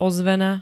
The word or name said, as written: ozvena